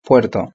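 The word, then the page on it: el puerto: Spanish (Spain)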